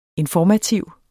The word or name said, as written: INFORMATIVE